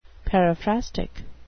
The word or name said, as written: periphrastic